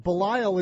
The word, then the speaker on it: Belisle: As he told us before we began the interview, originally was pronounced Belisle, just like Fletch's ancestral homeland and Fletch lives, but Belisle is the customary pronunciation after 13 years in the big leagues, and you're going to enjoy getting to know him.